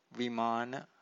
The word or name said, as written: vimana